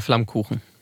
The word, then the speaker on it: Flammkuchen: Voy a pronunciar el plato que nos has traído hoy, que según lo leo es Flammkuchen, que no se dice así. ¿Cómo se dice? – Se pronuncia Flammkuchen.